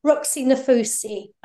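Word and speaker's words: Roxie Nafousi: I was told about the 7 Ways to Manifest book by, is it Roxy Nafasia? I don't know how you pronounce the name. Roxy Nafusi.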